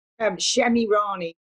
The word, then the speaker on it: Shemirani: Shemirani.